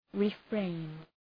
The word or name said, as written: refrain